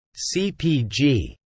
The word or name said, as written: CPG